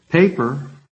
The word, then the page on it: PAPER: American English